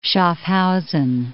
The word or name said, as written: Schaffhausen